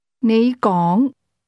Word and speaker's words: 你讲: 你讲, 讲, 你讲. – Those words are both pronounced with a rising tone. But you begins at a low level and speak at a higher level.